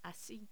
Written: asi

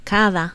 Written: cada

